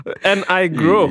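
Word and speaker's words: Nigro: He beat out D-Mile, he beat Hit Boy, Metro Boomin, and Daniel Negro. Negro? Sorry. – When I get that pronunciation right? – Yeah. Well, it's N-I-G-R-O. N-I-Grow.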